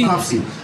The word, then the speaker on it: Pupsy: And my nickname was Pupsy. – Pupsy? – Pupsy. And so please spell that for the record. – P-U-P-S-Y, not I-E.